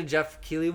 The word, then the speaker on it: Keighley: Back to the Jeff Keighley one.